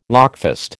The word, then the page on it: lockfast: American English